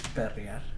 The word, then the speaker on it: Perrier: Perrier.